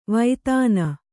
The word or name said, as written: ವೈತಾನ